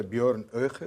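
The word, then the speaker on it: Björn Höcke: Para já, esta AFD, eh, é, mesmo dentro da AFD, AFD é, o líder da Turinja, a minha, a minha pronúncia, o Miguel vai corrigi-la, Bjorn Oeche, é? É, assim.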